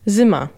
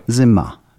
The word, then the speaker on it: zima: Tu akcent jest ważny jeszcze, nie? Bo tak. – Zapiszemy sobie to jako zyma normalnie, ale zyma, czyli na M, tak? – Na A. – Na A, dobra. Zyma, dobra.